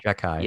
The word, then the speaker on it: Xhekaj: Zekaj is how you say his name. – Okay. – Zekaj. – Yeah. Spelled X-H-E-K-A-J.